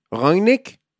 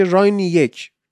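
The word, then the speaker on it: Rangnick: دو شیوه ایشون تلفظ میشه یکی رانگنیک یکی هم اون انگلیسی هم گفتش که راینی یک که راینی یک رو ورش کن خب اگرم گفتن به لحجه آلمانی رانگنیک براتون سخته همون رانگنیک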